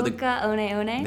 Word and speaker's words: Hoka One One: Say it one more time for Hoka One One.